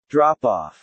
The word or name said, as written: drop-off